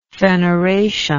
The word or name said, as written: feneration